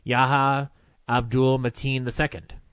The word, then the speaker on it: Yahya Abdul-Mateen II: Yahya Abdul-Mateen II.